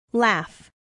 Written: laugh

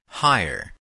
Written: hire

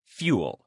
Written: fuel

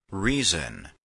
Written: reason

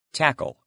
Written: tackle

